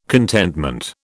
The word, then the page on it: contentment: British English